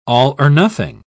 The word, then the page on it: all-or-nothing: British English